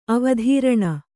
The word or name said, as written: ಅವಧಾರಣೆ